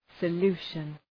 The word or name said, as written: solution